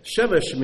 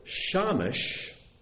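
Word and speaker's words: Shemesh (שמש): Shemesh means sun, but if you pronounce it Shamish, you don't have to change the spelling, pronounce it differently. Shamish, it means servant.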